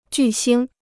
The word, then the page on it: 巨星: jù xīng